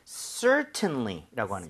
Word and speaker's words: certainly: 자, 그런데 얘는 강세가 앞에 이에 강하게 들어가요. – 네. – 그래서 어떤 경우에는 certainly 이렇게 깔끔하게 발음을 하는 사람도 있지만은 T를 거의 안 하는 사람도 있어요. 그럴 때는 certainly처럼 비슷하게 발음이 될 수도 있습니다.